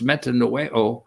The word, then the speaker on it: metanoeo: Or is the Greek word, which means the Greek word is pronounced metanoeo, it means a change of mind.